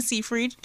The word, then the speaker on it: Seyfried: Seyfried? The one who played Karen? – I thought it was Seyfried. – There you go. Amanda Seyfried.